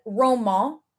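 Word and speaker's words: Romain: And then like Roman it's spelled romaine on Selling Sunset. And they all call him romaine, but French people pronounce it Roman.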